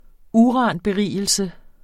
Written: uranberigelse

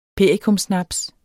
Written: perikumsnaps

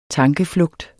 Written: tankeflugt

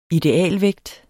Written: idealvægt

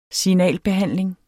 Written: Signalbehandling

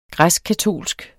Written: græskkatolsk

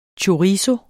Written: Chorizo

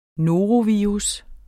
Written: norovirus

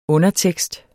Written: undertekst

